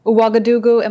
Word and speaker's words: Ouagadougou: And thank you so much for taking the time to talk to us today from Ouagadougou. Am I saying that correctly? – You are saying it correctly.